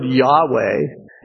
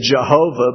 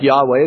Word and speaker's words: Yahweh: Some people say it as Jehovah, but that probably wouldn't be a good pronunciation for it. It's probably something like Yahweh.